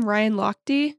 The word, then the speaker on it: Ryan Lochte: How do you say his last name? Ryan Lochte? – Yep.